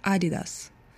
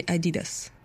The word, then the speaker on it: Adidas: Адольфа Дасслера называли Ади, поэтому он придумал такое название «Adidas», и ударение должно быть именно на первый слог. И поэтому даже на английском языке вроде как считают, что правильно произносить «Adidas», а не «Adidas».